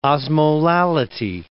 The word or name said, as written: osmolality